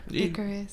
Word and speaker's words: Icarus: Icarus.